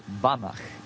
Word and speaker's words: Banach: Okay, I'm gonna do it in Polish. Banach is how you pronounce it. – Banach. – Yes, it means pach at the end.